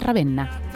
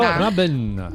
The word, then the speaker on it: Ravenna: que nos conducirán a descubrir la provincia de Ravenna.